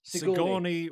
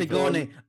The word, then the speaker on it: Sigourney: Sigourney Weaver. – Sigourney.